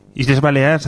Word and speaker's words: Illes Balears: Del nuevo patrocinio de Illes Balears, bueno, como se pronuncie en Illes Balears, así decía Perico Delgado, por lo menos.